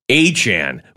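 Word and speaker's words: Achane: Learned today that his last name is pronounced Achan, whoever that running back is.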